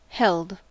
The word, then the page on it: held: American English